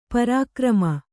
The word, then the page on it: ಪರಿಕ್ರಮ: pari krama